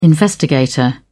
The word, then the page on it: investigator: British English